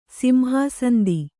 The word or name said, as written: ಸಿಂಹಾಸಂದಿ